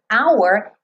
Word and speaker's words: hour: O H do hour não é pronunciado. Isso não é o padrão em inglês, certo? A gente sabe que em inglês o H típico é o H que tem o som do ha-ha-ha. Por isso a gente coloca ha-ha-ha-ha-ha com H-A. Ou então ho-ho-ho do Papai Noel. H-O, H-O, H-O, certo? Então, aqui, hour é uma palavra no inglês que o H é mudo.